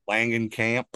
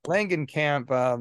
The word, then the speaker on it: Langenkamp: And Heather Landon, I can never say her name. – Langenkamp. – Langenkamp.